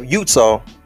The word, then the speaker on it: Eutaw: When I look at Greene County, Alabama, and the city of Utah, it's spelled E-U-T-A-W.